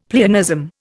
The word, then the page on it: pleonasm: Indian